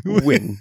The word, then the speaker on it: Nguyen: that Nguyen last name, like Nguyen. Like, yeah. I still can't pronounce it. Like, win.